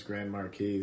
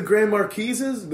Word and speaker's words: Grand Marquis: So you guys, the Grand Marquises? The Grand Marquis? Just pronounce the S. – Right, Just say the S. That's how we settled on it.